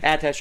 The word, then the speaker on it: attaché: Is it Attaché? – Attaché, yeah.